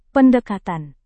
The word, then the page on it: pendekatan: Indonesian